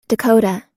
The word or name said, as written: dakota